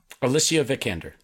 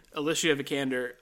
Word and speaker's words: Alicia Vikander: Alicia Vikander. – Alicia Vikander.